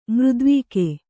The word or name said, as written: ಮೃದ್ವೀಕೆ